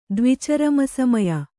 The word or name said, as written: ದ್ವಿಚರಮಸಮಯ